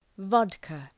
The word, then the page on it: vodka: ‘vɒdkə